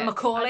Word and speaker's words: Macaulay: I don't know how to say it. – It's Macaulay.